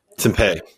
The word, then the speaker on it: Tempe: Tempe. Okay. That's that's how the, that's how the locals pronounce it.